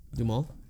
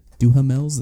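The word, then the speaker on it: Duhamel: Dumal? – That's how you pronounce it? – Yeah. – Duhamels? That's what I would have said. Duhamels.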